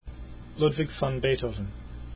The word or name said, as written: beethoven